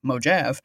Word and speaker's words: Mojave: and macOS Mojave, or as it's pronounced in some parts of the countries, Mojave.